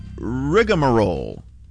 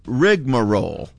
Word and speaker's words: rigmarole: The word of the afternoon is rigmarole or rigmarole. You can say it different ways. – Spell that for me. – Well, the first way is R-I-G-M-A-R-O-L-E.